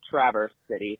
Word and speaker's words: Traverse City: I'm in with actually, just to clarify the pronunciation of the city, it's Traverse City, Michigan. – Traverse, not Traverse.